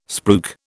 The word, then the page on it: spruik: British English